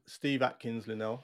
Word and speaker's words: Steve Atkins-Linnell: Okay, Steve Atkins-Linnell, a very, very warm welcome to the 10Q Interview.